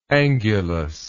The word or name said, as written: angulosity